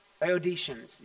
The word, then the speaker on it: Laodiceans: Did I say that right? Laodiceans, yeah.